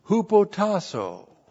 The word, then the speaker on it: hupotasso: He says, wives, hupotasso. H-U-P-O-T-A-S-S, long O. Hupotasso.